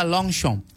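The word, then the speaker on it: Longchamp: Lu bacanya harusnya long shong. Long shong. Iya. Yeah. – Long jangan baca long cam.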